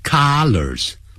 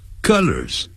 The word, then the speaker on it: colors: 所以要用 comes in, 所以一般呢用 comes in 会比较多，所以 this T-shirt comes in three colors, 哎，颜色不要念成 colors, 不是 R 的音，是饿的音，肚子饿的饿 colors colors, 那是 C-O-L-L-A-R 加个 S, 那是代表多数，叫衣领领子，我们说 the color of the collar is yellow.